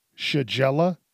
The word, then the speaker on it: Shigella: Shigella.